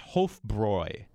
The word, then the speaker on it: Hofbräu: Now, everybody seems to think this is supposed to be pronounced Hofbrau, which is understandable, but that umlaut changes it, and it needs to be pronounced Hofbräu, an oi sound.